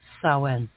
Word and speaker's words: Samhain: It's spelled S-A-M-H-A-N. It is pronounced Samhain.